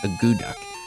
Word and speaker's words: akutaq: There's no actual duck in it. It's actually spelled A-K-U-T-A-Q. – Okay. – But it is pronounced a goo duck.